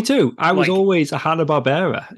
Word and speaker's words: Hanna-Barbera: Is it Hanna-Barbera or Hanna-Barbera? Whichever one. – Hanna-Barbera, yeah. – It is Hanna-Barbera? Right.